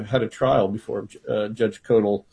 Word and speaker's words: Koeltl: One quick thing, Emmanuel, the judge that you mentioned, is it spelled K-O-E-L-T-L? – Yeah, I was kind of hard-pressed how to pronounce that. – Yeah, that's a weird one. That's Judge Codal, actually.